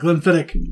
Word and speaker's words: Glenfiddich: His bottle of Glenfiddich.